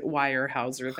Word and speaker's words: Weyerhaeuser: They pronounced it Weyerhaeuser then, that old German name.